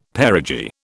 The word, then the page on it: perigee: British English